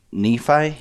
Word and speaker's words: Nephi: Yeah, last time I came down here, you guys were in Nephi? Is that how you say it? – Yep.